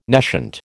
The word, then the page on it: nescient: American English